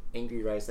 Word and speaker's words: Angourie: On her Instagram, she like puts how to pronounce her name too. – What is it? – It's like Angry Rice.